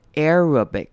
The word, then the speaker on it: Arabic: 那接下来我们要看到 Arabic 这个字也是比较难念的，阿拉伯文这个字它开头的 A-R 要念成 air 的声音，念成长母音 A， Arabic， Arabic，中间的 A 念成轻音，最后的 I 呢念成短母音 I，然后重音在第一个音节 Arabic， Arabic。